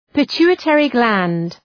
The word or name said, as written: pituitary gland